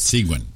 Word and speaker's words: Seguin: Only a true Texan can pronounce it right. Seguin.